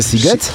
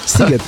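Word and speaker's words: Sziget: wierzę, że pisze się Shiget, a wymawia się Siget? Siget.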